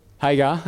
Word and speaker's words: Hagar: Hagar, okay.